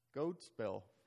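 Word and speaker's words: gōdspel: But Old English, it used to be goad spell, from goad meaning good and spell meaning story, a goad story. Notice it's a long O.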